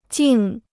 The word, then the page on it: 静: jìng